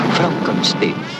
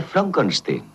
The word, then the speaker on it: Frankenstein: ¿Doctor Frankenstein? Frankenstein. ¿Me toma el pelo? – No, se pronuncia Frankenstein.